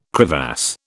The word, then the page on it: crevasse: British English